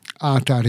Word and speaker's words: qatar: Qatar ix-xbieb hija ponta ħamra fil-wiċċ waqt l-iżvilupp. Alright? Qatar fis-singular għandha l-aċċent fuq l-ewwel sillaba. Qatar. Għaliex qed ngħidlek, għax fil-plural għandha l-aċċent fuq it-tieni sillaba, qatar.